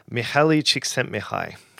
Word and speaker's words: Mihaly Csikszentmihalyi: Mihaly Csikszentmihalyi.